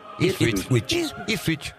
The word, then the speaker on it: Ipswich: Ipswich.